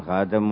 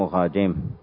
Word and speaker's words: خاتم: خاتم واحد، وقيل يجوز تعدد الخاتم. يقول باب ما جاء في لبس الخاتم، فينطق بفتح التاء وبكسرها خاتم وخاتم،